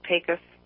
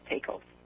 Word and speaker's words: Pecos: And the correct pronunciation is Pecos, not Pecos.